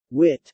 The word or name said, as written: wit